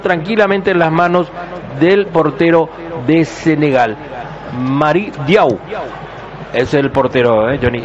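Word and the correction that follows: Mory: ¿O es Mori o es Mari? Para mí que es Mori. – Mori, bueno.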